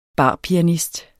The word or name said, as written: barpianist